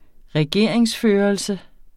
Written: regeringsførelse

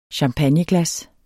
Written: champagneglas

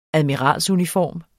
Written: admiralsuniform

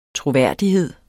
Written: troværdighed

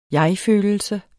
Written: jegfølelse

